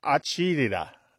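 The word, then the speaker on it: acidità: And it's pronounced something like acidita, acidita.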